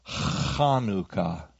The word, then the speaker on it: Hanukkah: or Hanukkah, as we might say in Texas, or Hanukkah. We get confused because sometimes it's spelled C-H and sometimes it's spelled H. And the reason is whether it's anglicized or left in the Hebrew transliteration, and it's Hanukkah. But some of us have a problem with that guttural sound, and so it's okay if you say Hanukkah, or some of us say Hanukkah.